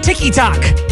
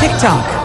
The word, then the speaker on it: TikTok: Number two, you call it the Tiki Tok. You idiot. It's, it's TikTok.